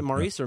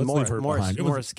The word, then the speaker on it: Maurice: Is it Maurice or Morris? Because, you know, Morris, well, it's pronounced Morris Gibb is how you pronounce that.